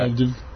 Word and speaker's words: Plovdiv: Plovdiv. Plovdiv u Bugarskoj.